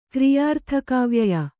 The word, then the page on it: ಕ್ರಿಯಾರ್ಥಕಾವ್ಯಯ: kannada